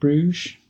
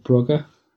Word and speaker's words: Brugge: I'm going to start with Bruges, Club Bruges, or Club Brugge, as you're supposed to pronounce it correctly.